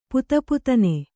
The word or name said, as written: ಪುತಪುತನೆ